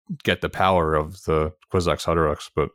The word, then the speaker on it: Kwisatz Haderach: that he has to die to become the Kwisatz Haderach.